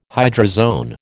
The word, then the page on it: hydrozoan: American English